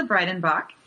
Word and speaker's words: Breidenbach: My name's Angela Breidenbach.